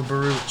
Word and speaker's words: Barhout: So, yeah, check out the Cave of Barut, which is spelled B-A-R-H-O-U-T. Well, Barut, check it out, folks.